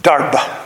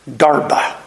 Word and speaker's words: Derbe: Derbe. It's Greek. Derbe. It's not Derby. It's not Derb. It's Derbe. The E is pronounced, but not as an E.